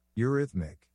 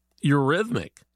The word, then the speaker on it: eurhythmic: Today it is spelled E-U-R-H-Y-T-H-M-I-C. – Eurythmic. – Eurythmic.